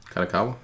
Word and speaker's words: Kadokawa: Kadokawa Games. Did I say that right? – Kadokawa, yeah.